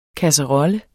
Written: kasserolle

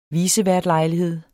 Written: viceværtlejlighed

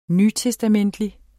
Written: nytestamentlig

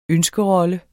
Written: ønskerolle

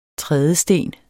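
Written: TRÆDESTEN

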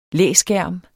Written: læskærm

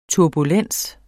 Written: turbulens

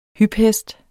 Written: hyphest

